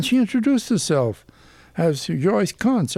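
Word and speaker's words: Kuntz: K-U-N-T-Z. I says, I'm German extraction. My father spoke German. I says, in Germany, that's pronounced Kunz. She said, that's silly. It's that. No, it's Kunz.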